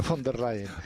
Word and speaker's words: Von der Leyen: a ver si lo digo bien, ¿cómo se dice, tú que hablas y demás? Von der Leyen, se dice bien, la señora Von der Leyen, ¿no? – Von der Leyen. – Ah, Von der Leyen, sí.